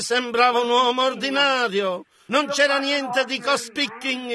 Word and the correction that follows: cospicuo: in merito a ciò cospicuo, piuttosto, perché la Scrittura disse, non c'era bellezza perché lo desiderassimo.